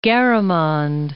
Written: Garamond

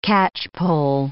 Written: catchpoll

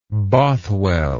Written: Bothwell

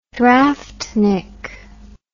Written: draftnik

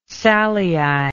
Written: salii

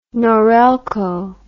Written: Norelco

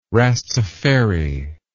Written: Rastafari

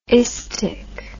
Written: istic

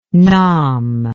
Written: Nam

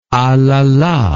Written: alala

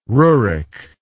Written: rurik

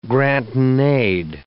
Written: grant-in-aid

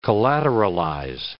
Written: collateralize

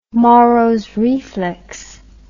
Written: moros reflex

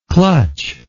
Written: clutcher

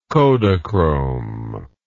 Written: Kodachrome

